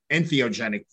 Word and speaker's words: entheogenic: the personal possession of and therapeutic use of ethnogenic plants. Did I say that correctly? – Entheogenic.